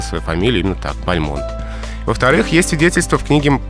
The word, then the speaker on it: Бальмонт: Мне приходилось слышать, и в некоторых словарях и энциклопедиях дается «Бальмонт». Хотелось бы рассказать, почему все-таки «Бальмонт». – Ну, потому что, во-первых, французская. – И потому что сам поэт произносил свою фамилию с ударением на последнем слоге.